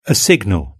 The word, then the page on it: a signal: British English